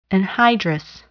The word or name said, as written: anhydrous